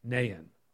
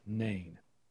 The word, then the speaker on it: Nain: Walk with me, as now we're walking into the city of Nain, or some pronunciations are just Nain.